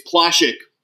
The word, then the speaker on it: Plashek: And it's Plashek, like Hashik with a PL, by the way.